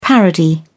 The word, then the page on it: parody: British English